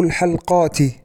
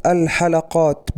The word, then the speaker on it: الحلقات: العبارة الرابعة: قال المذيع أصعب الحلقات. الخطأ في كلمة الحلقات، حيث نطق المذيع الكلمة بتسكين اللام، والصواب الحلقات بفتح اللام،